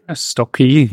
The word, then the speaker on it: Stokke: And a global e-commerce operations manager for Strock. Am I pronouncing it correctly? – Stokke, yeah.